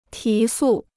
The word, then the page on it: 提速: tí sù